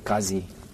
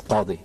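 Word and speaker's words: Qadhi: So, of course, they would pronounce it Qazi, right? – Yes. – But when I was in my teenage years, I wanted the authentic pronunciation. So I began to write it and pronounce it as Qadi.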